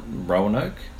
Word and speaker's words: Roanoke: Roanoke? – So I am saying it right, Roanoke. – Yeah, as far as I'm aware, that's pretty much how I say it, Roanoke.